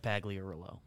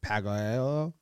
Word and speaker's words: Pagliarulo: Mike Pagliarulo. – You sound like you're speaking French, where you're just like not confident in the last two syllables of it. Pagliarulo.